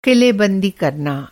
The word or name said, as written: किलेबंदी